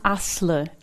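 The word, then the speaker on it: Aslı: Und im türkischen Alphabet, da gibt es das I ohne Punkt. Und also wohl Asli, also Asle wird ohne I-Punkt geschrieben und Kilic auch ohne I-Punkt Kilic.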